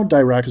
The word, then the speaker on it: Dirac: And it's D-I-R-A-C, all capitalized and pronounced DIRAC for some reason.